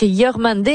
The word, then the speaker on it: Yormandé: C'est la chanson de Zalsek, Yormandé. Est-ce que ma prononciation est bonne, Zalsek? – Très bien. Tu prononces très bien le Wolof.